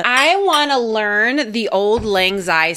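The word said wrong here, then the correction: Auld Lang Syne: song. Don't know it. I feel like that should be something. – First of all, you should just learn how to say it. – That's not... I didn't say it right? – No. – Don't keep me... – Old Lang Syne, right? – Isn't that how you say it? – What did you say?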